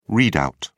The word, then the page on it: read-out: British English